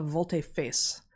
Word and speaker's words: volte-face: A volte-face.